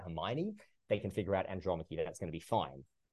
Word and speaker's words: Andromache: what I just say, you know, Andromache, this is how you pronounce it, and it comes from the story of the Trojan War.